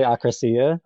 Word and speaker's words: akrasia: Correct my pronunciation. Is it akrasia? It's this gray area between free will. – Yeah, I've heard philosophy professors pronounce it all different ways, so I think you're fine. But I like to say akrasia.